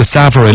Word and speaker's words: separate: 今天节目里头说那个 separate 的那个重音是在发上头，还有之前那个典型的广告那个 fiber to room 那个 fiber 发音应该是 fiber 啊，王黑石天天听你读，有时候呢就开始怀疑自己了。